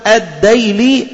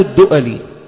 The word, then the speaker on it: الدؤلي: الديلي أو الدؤلي، بننطقها كده أو كده، هذه فائدة.